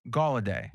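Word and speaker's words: Golladay: Listen, even with Galladay, how do you say his name? – Galladay. Yes. – Like Gollum? – Gall, yeah.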